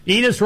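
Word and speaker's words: Enes: Enos rhymes with penis.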